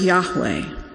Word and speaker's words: Yahweh: And it is a translation for the Hebrew word for consonants that we would pronounce Yahweh.